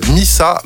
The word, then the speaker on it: Misa: On aura une découverte qui s'appelle Missa, mais alors attention, M-I-S-A. – Ok. – Voilà, ça se prononce, mais ça s'écrit pas pareil que Missa, de je ne sais plus où il est.